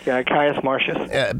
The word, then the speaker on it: Caius Marcius: And last week we talked about two very interesting men, Coriolanus and Alcibiades, and we didn't finish really with enough of Coriolanus, Caius Marcius, is that how you say it? Marcius? – Caius Marcius.